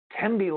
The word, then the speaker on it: Tembi: Also, next week, oh, I'm going to have Tambi, Tembi, ah, let me get her name right. – Tembi Locke, oh, actress, and she's got a book out, a memoir, blowing up.